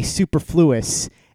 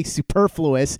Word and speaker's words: superfluous: I used to say superfluous, and then one day I heard somebody say superfluous, and I was like, oh, that's how you say it.